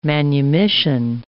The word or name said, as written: manumission